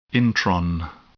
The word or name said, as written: intron